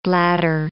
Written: blatter

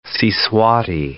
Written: Siswati

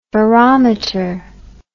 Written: ferrometer

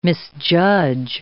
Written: misjudge

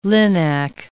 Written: linac

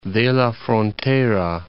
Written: Jerez de la Frontera

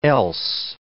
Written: else

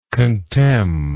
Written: contemningly

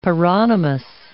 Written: paronymous

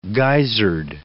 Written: guisard

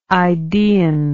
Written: idaean

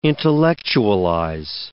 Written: intellectualize

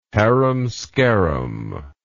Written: harum scarum

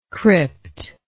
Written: crypt

